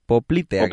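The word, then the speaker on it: poplítea: la arteria poplítica. Poplitea, creo que se dice así.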